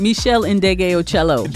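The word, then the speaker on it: Meshell Ndegeocello: I probably butchered her name. – Oh, so. – Michelle Ndege Ocello. – And there it is.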